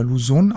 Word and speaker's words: Luzon: Die Clark Airways Base ist ein früherer Luftwaffenstützpunkt der US Air Force auf der philippinischen Insel Luzon oder Luzon ausgesprochen.